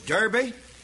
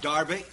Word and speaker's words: Derbe: And then, following Lystra, there was Derbe. And as I said before, I will sometime or other call that Derbe, because the English city, D-E-R-B-Y, is in English pronounced Derbe, same as C-L-E-R-K is pronounced Clark.